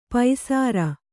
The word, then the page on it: ಪೈಸರ: paisara